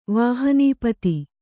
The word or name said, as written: ವಾಹನೀಪತಿ